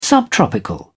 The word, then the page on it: subtropical: British English